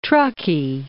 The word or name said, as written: Truckee